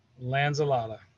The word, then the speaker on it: Lanzilotta: And for anyone that doesn't know, this is Gus from profile, and Gus's last name is correctly pronounced Lanzilotta.